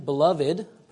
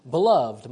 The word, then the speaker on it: beloved: If it's used as a noun, you use the three-syllable pronunciation, beloved. Perhaps some of you have been to a wedding where you've heard dearly beloved. It's being used as a noun, so you use the three-syllable pronunciation. However, if using it as an adjective, you use the two-syllable pronunciation, beloved, my beloved wife, or in this case, my beloved brethren.